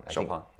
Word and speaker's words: Chopin: Chopin. I don't know how to say it, Polish. Ways pronounce it, but that's how you pronounce it. – Chopin.